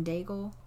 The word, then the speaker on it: Daigle: You know it if you heard it, but it's You Say by Lauren Daigle.